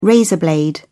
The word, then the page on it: razor blade: British English